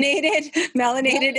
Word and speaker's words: melanated: melaninated. Am I saying that right? I'm laughing. – Melanated.